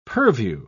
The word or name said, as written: purview